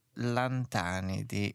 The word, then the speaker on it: Lantanidi: Lantanidi, e si pronuncia così? Ho sbagliato? – Si pronuncia esattamente così.